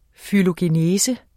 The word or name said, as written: fylogenese